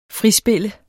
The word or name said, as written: frispille